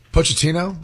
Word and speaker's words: Pochettino: By the way, Pochettino, is that how you pronounce the coach's name? – Yeah.